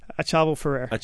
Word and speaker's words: Achaval Ferrer: We had him up for a great tasting and for our Cheval Ferrer. How do you say it? – Achaval Ferrer. – Achaval. I got to say that.